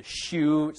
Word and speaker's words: Xiu: This is a Yucatec Maya dynasty called the Xiu, spelled X-I-U, but pronounced like a shoe.